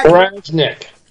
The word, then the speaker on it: Wirasnik: Wirasnik.